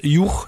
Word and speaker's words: Hugh: Auch im Englischen, wie spricht sich das aus? Juch, ne? H-U-G-H geschrieben, alter Vorname.